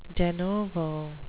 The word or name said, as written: de novo